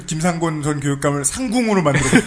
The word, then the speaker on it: 김상곤: 장학대단과 경기 고교 평준화 시민연대 같은 곳에서 일하기도 했고 김상공 김 김상공 김상곤 예.